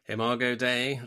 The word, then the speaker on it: Imago Dei: Am I saying that right? – Imago Dei, that's right.